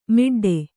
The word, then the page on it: ಮಡ್ಡು: kannada